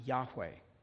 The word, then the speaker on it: Yahweh: The rough English equivalents are YHWH, a name that is almost certainly pronounced Yahweh.